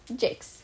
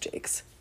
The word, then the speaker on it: Jacques: Alex Jakes? – Jakes, sure. – But it does kind of confine them to... – It does look like that, but I've heard it pronounced Jakes.